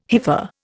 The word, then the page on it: hypha: Indian